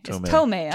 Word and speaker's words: Tomei: Is it Tomei or Tormé? Tomei.